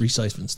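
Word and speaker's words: Rhys Ifans: I never say his name right. – Yeah. Reese Ifans, I think it is. – Ifans, Reese Ifans.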